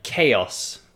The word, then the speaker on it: chaos: So we have words like chaos, which I think pretty sure is from Greek. Chaos is spelled C-H-A-O-S. Maybe you couldn't guess that pronunciation. And we have words like cliché from French, which is just C-L-I-C-H-E with a, well, sometimes we put the accent, the rising accent at the end.